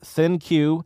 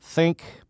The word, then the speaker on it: ThinQ: The pronunciation of this smartphone is ThinQ, not think, but you know, whatever.